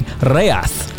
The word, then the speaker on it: Ghaiyyath: Ghaiyyath, to give him his proper Arabic pronunciation.